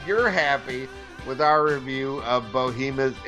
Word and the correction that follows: Behemoth: Is it Bohemoth or Behemoth? Am I pronouncing this right? – I think it's Behemoth. I think it's that. – All right. – Mean, like, you know, it's an English word.